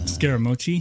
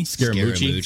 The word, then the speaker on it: Scaramucci: Scaramucci. – Scaramucci.